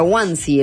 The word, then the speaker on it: onesie: Sí, a Isabel, con su que aprendimos que se llamaba, después nos comentaba, se pronuncia Wansi, el mameluco es el pijama.